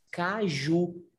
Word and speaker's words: caju: Because in Portuguese we call that caju, C-A-J-U, caju, you know? And in English, cacheu, and we say cashew.